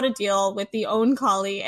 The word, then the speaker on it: Oankali: I yeah, no, that you you you keep going back and forth between two pronunciations. I think it's supposed to be own collie.